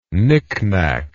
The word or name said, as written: nicknack